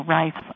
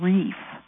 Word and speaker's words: Rife: My ancestors came to America in the late 1700s and there are Rifes all across the country now. Some of the families pronounce the name Reef, but the German pronunciation is Rife.